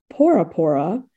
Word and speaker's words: Bora Bora: It's actually pronounced Pora Pora. But it was likely that the early visitors misheard it, and it's been Bora Bora ever since, which is just the saddest statement of how we change things.